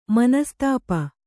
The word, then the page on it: ಮನಸ್ತಾಪ: manastāpa